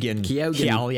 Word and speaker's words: Keoghan: Keoghan.